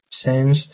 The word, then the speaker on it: Sens: Esto es la Catedral de Sens, es de Sens, pronunciado literalmente, Sens, el pórtico central de la fachada occidental.